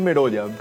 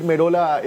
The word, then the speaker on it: Merolla: Merolla o Merola, seguramente, pero creo que la pronunciación es Merolla. Pero acá le van a decir Merola está tocado y no llega para el partido del día viernes.